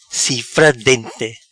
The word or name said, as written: Cifra Dente